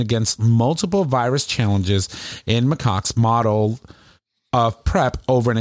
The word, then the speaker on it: macaques: By the way, it's pronounced macaques. – Macaques. Okay. – Yeah, macaques. – Macaques. – Not my cocks.